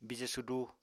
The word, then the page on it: bije sudu: Apatani